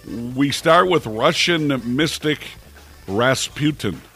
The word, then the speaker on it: Rasputin: Rasputin. – Yes, that's how they pronounce it there in Europe.